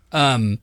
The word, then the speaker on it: UEFA: I'm assuming that's how you say it, UEFA? – UEFA.